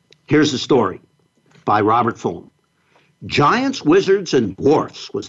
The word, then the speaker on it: Fulghum: And this is by Robert Fulham, F-U-L-G-H-U-M. It's pronounced Fulham.